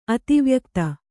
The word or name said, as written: ಅತಿವ್ಯಕ್ತ